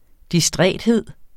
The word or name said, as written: distræthed